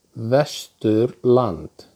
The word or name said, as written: Vesturland